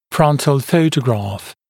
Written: frontal photograph

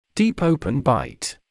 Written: deep/open bite

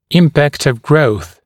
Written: impact of growth

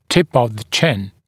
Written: tip of the chin

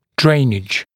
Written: drainage

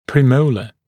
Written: premolar

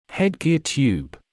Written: headgear tube

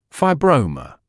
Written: fibroma

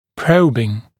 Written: probing

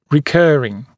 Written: recurring

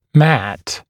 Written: matt

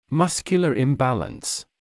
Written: muscular imbalance

